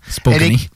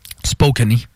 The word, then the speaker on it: Spokane: Ça s'écrit Spokane, par exemple? – Oui, oui, mais ça se prononce Spokane. – OK. – C'est comme, il n'y avait pas une bière qui... la Kokanee, mais Spokane.